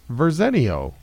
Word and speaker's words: Verzenio: I'm like, Verzenio. And he's like, Verzenio. I was like, Just think Arsenio, but... – Arsenio, but with a ver.